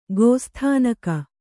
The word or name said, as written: ಗೋಸ್ಥಾನಕ